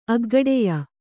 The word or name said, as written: ಅಗ್ಗಡೆಯ